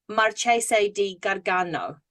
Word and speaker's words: Marchese di Gargano: he got the king to make him Marchese di Gargano.